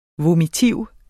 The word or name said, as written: vomitiv